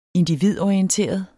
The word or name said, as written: individorienteret